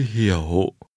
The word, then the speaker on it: hiểu: Nhưng tôi hiểu. Hiểu. – Remember to pronounce understand with a falling, rising tone.